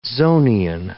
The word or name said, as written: Zonian